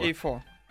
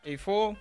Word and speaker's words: Afour: Эйфо. Эйфо, как от слова эйфория.